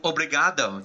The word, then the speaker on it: obrigado: O- obrigado.